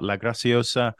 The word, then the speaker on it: La Graciosa: If you want to check out La Graciosa, I guess it is a kind of a soft C in that part of the world, not a th kind of C like Spanish Spain. – It is. It's an S sound. Yeah. – An S sound. Right.